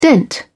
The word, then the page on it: dent: British English